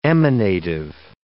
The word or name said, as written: emanative